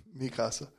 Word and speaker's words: Mikasa: I need an Aaron, a Masaka. Did I say that right? – Mikasa. – Mikasa.